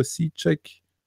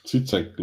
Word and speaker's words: Žižek: Slavoj Žižek? Wie spricht man es richtig aus? – Žižek, glaube ich.